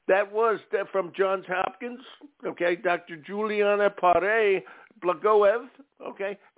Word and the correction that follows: Blagoev: and also, actually I pronounced that wrong, Blagoev,